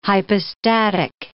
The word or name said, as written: hypostatic